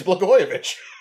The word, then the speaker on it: Blagojevich: Blagojevich. – That's not it, but yeah. – No, that is it. It's Blagojevich.